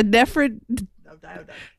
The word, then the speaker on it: nephridioduct: How would you pronounce this? – Wait, can you highlight it? Oh, a nephriduoduct? – Yes, a nephridioduct.